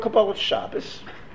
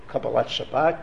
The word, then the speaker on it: Kabbalat Shabbat: Kabbalat Shabbos, Kabbalat Shabbat, if I were pronouncing it properly.